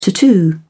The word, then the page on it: tattoo: British English